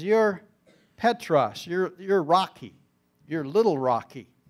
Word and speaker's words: Petros: The word Peter, in Greek, is pronounced Petros.